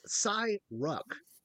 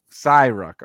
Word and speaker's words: Ssi-ruuk: Was the invading forces of the Ssi-ruuk, a species from the unknown regions, I think it's Cy Ruck. – Cy Ruck.